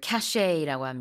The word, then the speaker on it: cachet: It's about people looking at you, admiring you, thinking you're great if you have this cachet. – 뭐 품격이라든지 위신 이런 것들을 cachet라고 합니다. 마지막 T는 발음하지 않아요.